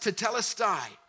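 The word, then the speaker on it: tetelestai (τετέλεσται): It's called, it's pronounced tetelestai.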